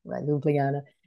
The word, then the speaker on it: Ljubljana: Yeah, I actually struggled with the pronunciation, you know, the whole time we were there. And I think I have it now, Ljubljana. Ljubljana.